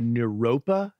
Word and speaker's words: Naropa: The Naropa Institute? – Correct.